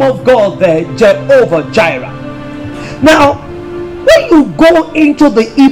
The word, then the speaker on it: Jireh: Jireh is spelled J-I-R-E-H, but it's pronounced Ra, Jireh, Jehovah Jireh.